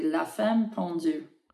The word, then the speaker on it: La Femme Pendue: With the French accent, it would be La Femme Pendue.